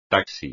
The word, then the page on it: taxi: espagnol